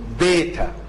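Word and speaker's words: بيتا (βήτα): الحرف اللي بعديه ده حرف بيتقري باليوناني بيتا، ميلة كده بيتا، عشان احنا ما عندناش في العربي الميلة دي ولا في الانجليزي الميلة دي فبنكتبها بيتا، لكن هي باليوناني بتتنطق بيتا ميلة.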